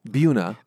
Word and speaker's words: Buena: Buena.